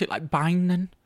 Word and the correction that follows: benign: Benign. – Benign, that's it. Benign, not binding.